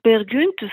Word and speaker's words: Peer Gynt: on prononce Peer Gynt, ça s'écrit Peer Gynt, c'est très connu aussi, c'est une série de morceaux autour d'une pièce de théâtre.